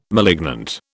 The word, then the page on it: malignant: British English